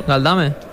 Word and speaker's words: Galdames: Galdames. – Yo creo que sí. De hecho, lo he buscado un par de veces y no aparece con acento en la... No es esdrújula, que vaya.